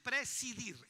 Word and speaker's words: presidir: Pasemos al siguiente don, si lo podemos llamar, es el don de precedir, o ¿cómo se dice? Presidir, esa es la palabra correcta, presidir.